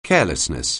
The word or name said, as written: carelessness